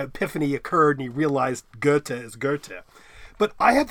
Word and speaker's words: Goethe: She said, pronounce it, think about an O, but don't pronounce it. Say O-O while thinking intently about the letter R. But don't pronounce the R. So Goethe. I can't do it.